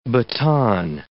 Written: baton

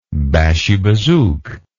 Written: bashi bazouk

